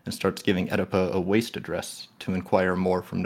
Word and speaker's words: W.A.S.T.E.: He refuses to say any more than explaining that waste is pronounced one letter at a time. W-A-S-T-E.